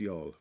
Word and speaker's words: Youghal: It's spelled Y-O-U-G-H-A-L, but it's pronounced Yall.